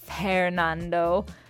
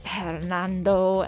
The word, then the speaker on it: Fhernando: Fernando before that, or shall I say Fernando. We've got a lot of silent H's here in the podcast today. F-H-E-R-N-A-N-D-O, Fernando, and the song was called The Last Days of Disco.